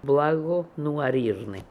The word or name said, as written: Blagonooarirnian